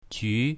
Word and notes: ju2: mandarin

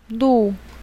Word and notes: lu4: mandarin

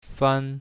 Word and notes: fan1: mandarin

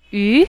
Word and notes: yu2: mandarin

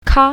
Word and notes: ka1: mandarin

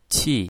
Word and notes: qi4: mandarin

